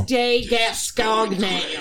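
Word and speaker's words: Côtes de Gascogne: Côte de Gascogne.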